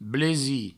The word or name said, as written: blesi